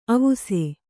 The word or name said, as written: ಅವಸೆ